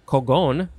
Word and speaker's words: cogon: Um, so this is a it's from a Spanish word, cogon, with an accent on the second O, uh, from Visayan and Tag Tagalog, uh, from their word kugon with a K.